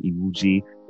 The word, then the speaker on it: Iwuji: I'm mainly on Instagram, Facebook, and LinkedIn, but people can just type in my name on there, Jesse Iwuji. That's J-E-S-S-E. Last name is I-W-U-J-I.